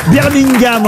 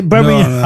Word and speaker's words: Birmingham: Birmingham, en tout cas. On ne doit pas prononcer le G, hein, il paraît.